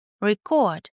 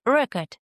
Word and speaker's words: record: В качестве разновидности конверсии можно рассматривать образование глаголов от существительных путем переноса ударения с первого слога на второй. Record — записывать, и record — запись.